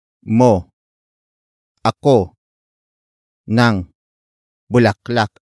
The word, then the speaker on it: ako: The next word is ako. Ako. Ako. Ako is spelled as A-K-O.